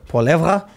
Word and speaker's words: Poilievre: Poilievre.